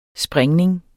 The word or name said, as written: springning